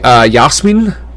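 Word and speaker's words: Jasmijn: Because it's J-A-S-M-I-J-N. Would that be Yasmin? Am I close there?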